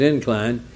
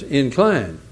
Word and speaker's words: incline: For instance, the word incline, when it's a noun, is incline. When it's a verb, it's incline.